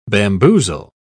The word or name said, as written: bamboozle